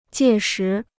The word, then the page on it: 届时: jiè shí